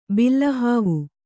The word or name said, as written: ಬಿಲ್ಲಹಾವು